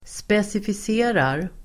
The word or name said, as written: specificerar